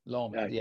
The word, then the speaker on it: Lomond: So we would go to in my pronunciation, I'm probably butchering things now, but is it Lomond or Lomond? – Lomond.